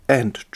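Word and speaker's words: v: Wenn es nämlich um äh, Gerichtsfälle, die keine Strafprozesse sind, ähm, geht es nach dem, Sch, äh, wird es ausgesprochen nicht Smith versus Jones, sondern Smith and Jones. In Strafprozessen dagegen nicht The Crown versus Smith, sondern The Crown against Smith.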